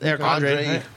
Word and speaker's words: Eric Andre: It's Nick Andrade. Or how do you say his name? – It's Eric Andre.